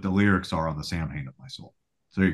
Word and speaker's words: Samhain: So I think that was more about just stop trying to get people to pronounce it the way he pronounced it correctly. Cause the average person is going to read that as Sam Hain. Yeah. And so he gave up on that, but hence it's called Sam Hain. But in all the songs, he pronounces it Samhain.